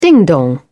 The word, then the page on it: ding-dong: British English